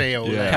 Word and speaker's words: café au lait: Café au lait.